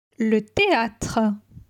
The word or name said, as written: Le theatre